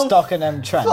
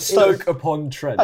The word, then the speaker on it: Stoke-upon-Trent: So is it pronounced as Stoke-upon-Trent or Stockenham-Trent? – Oh, fuck's sake. He calls it Stockenham-Trent. Stockenham-Trent. – It's Stoke-upon-Trent.